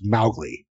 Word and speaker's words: Mowgli: His daughter, like, said that she never, like, the Kipling estate never forgave Disney because Mowgli is supposed to be pronounced Mowgli, and, like, completely changed the pronunciation forever.